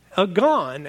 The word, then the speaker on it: agon: But when you dig a little deeper, you got to mention the fact that that word ago actually forms the root for the word agon, A-G-O-N. Again, I'm sure that's how you pronounce it.